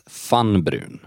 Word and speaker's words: Vanbruun: Fann Brun stavas V-A-N-B-R-U-U-N. Det kan vara bra att komma ihåg. – Men uttalas Fann Brun.